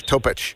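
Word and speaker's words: Topić: It's usually a chich sound at the end of those. Topic.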